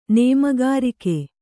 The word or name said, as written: ನೇಮಗಾರಿಕೆ